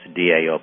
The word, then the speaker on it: Tao: It's pronounced DAO, but it's TAO, the Tao.